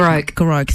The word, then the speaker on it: Goroke: Erin's in Hopetown and in Goroki. Have I pronounced that correctly? – Goroke. – Thank you. As that was coming out of my mouth, I'm like, I think I'm mispronouncing that town.